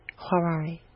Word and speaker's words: Hwarari: Huarari. – Okay. – H-W-A-R-A-R-I.